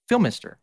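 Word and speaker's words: Phimister: Don't say the L. It's like Phil, but Filmister. – Okay. Filmister.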